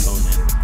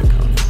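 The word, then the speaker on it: Connan: I think it's pronounced Conan. – No, it's actually Conan.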